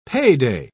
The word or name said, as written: payday